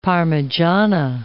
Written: parmigiana

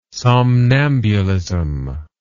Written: somnambulism

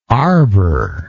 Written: arboresque

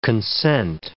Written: consent

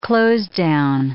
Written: closedown

